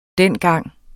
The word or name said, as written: dengang